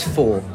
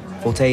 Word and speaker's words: forte: It's four, actually. Forte is Italian, it means forcefully, and four is French for strength. But I still say forte too, because if you say four, everyone thinks you're getting it wrong, even though it is the correct pronunciation.